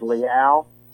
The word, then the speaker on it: Leal: DeMarvin and the Texas pronunciation for you, Brett, and Harold, and all your listeners is Leal. – Okay, thank you. – Yeah, that's the Texas pronunciation there. It's a little bit different.